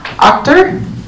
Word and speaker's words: Achter: And does someone know exactly how to say the name? – Achter. – In German, it would be Achter, just so you know. It looks maybe German to me.